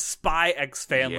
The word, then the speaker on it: Spy x Family: and that is Spy X Family. – Yes. – And yes, I'm going to pronounce the X.